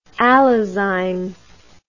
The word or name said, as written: allozyme